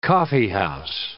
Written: coffeehouse

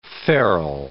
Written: ferrule

